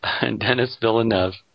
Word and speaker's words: Denis Villeneuve: I think it's Denis because he's a Quebecois.